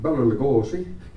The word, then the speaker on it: Bela Lugosi: And the day that Bela Lugosi, now I'll pronounce him Eastern European, Bela Lugosi, okay, was introduced to us, he was always late, in the big sound studio, we're all waiting for him, okay?